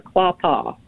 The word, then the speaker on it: Quapaw: Quapata. Did I pronounce that right? – Quapaw. – Quapaw and the Osage. – Yeah, the Caddo, the Osage, and the Quapaw.